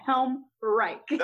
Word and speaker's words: Helmreich: I'm like, and I introduce this is going to sound terrible, but think of Nazi Germany and the Third Reich. That is how you say my name, Helmreich.